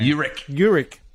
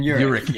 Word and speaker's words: Urich: Urich. Wølfdarling, yes. And Urich, yes.